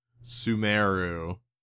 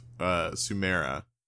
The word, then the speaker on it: Sumeru: So, as I said, like the very first thing I told you is that I hate the fact that it's called Sumeru instead of Sumera.